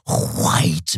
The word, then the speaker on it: white: And by the way, if you don't pronounce the H before the W, you're doing it wrong. It's not white men. It's white men, like Mr. Musk.